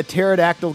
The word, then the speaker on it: pterodactyl: Why can't you hear a pterodactyl go to the bathroom? – I don't know. – Because the P is silent.